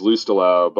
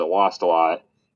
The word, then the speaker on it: Lustalo: L-O-S-T, like my last name is Lustalo, but my dad's family pronounces it Lost a lot.